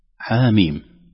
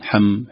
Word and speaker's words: حم: في العادة لو لم نسمع كيفية تلاوتها قد يقرأها الإنسان حم حم حم أي شيء، لأن احنا في العادة لا ننطق الحرف بلقبه أو بصورة، لكن ننطق الحرف كحرف مبنى بفتحة ضمة كسرة كذا. لكن هذا يدل على أن القرآن متلقى، أننا ما قرأناها حم أو حم أو كذا، قرأناه حاميم، لم نقرأه حاء ميم أيضا، رغم أن نقول ألف باء تاء ثاء في نطق الحروف الهجاء نضع الهمزة.